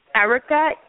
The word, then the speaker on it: Erika: Erica, E-R-I-K-A.